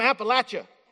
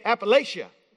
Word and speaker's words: Appalachia: It's like, you know, I'm from Appalachia, okay? But some of you say Appalachia. That's not right. That's the incorrect way to say that. And as the Eastern Kentucky Cultural Ambassador, let me tell you, there's no sound in that, all right?